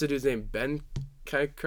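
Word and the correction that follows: Bentancur: or whatever. – Ben Tanker, yeah.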